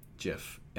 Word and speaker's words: GIF: So I watched a video of the creator of the GIF, and he was on stage, and they said, "How is it pronounced?" And he said, "Jiff."